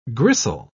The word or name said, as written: gristle